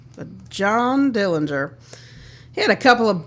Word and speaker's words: Dillinger: I was surprised because he pronounced his name with a hard G, like Dillinger. Of course, I heard of him, but I stood there for a moment wondering if he knew how to say his own name. Well, of course, that's the way the family said it, and that was the right way.